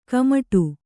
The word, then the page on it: ಕಮ್ಮಡು: kammaḍu